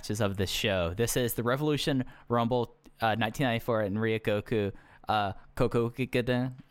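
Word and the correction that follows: Kokugikan: I always mess up pronouncing it. It's at Sumo Hall. Sorry. – I can't believe people just don't say Sumo Hall. It's the only way I'll ever refer to it. – Kokugigen. There we go.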